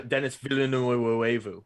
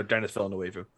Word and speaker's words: Denis Villeneuve: Denis Villeneuve. – Okay. – Or Denis Villeneuve.